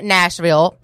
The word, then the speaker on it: Gnashville: But anyway, so yeah, that was En L'Or with their song Nashville, but it's spelled with a G, as in like Gnashville, as in gnashing one's teeth, perhaps.